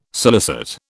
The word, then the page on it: scilicet: British English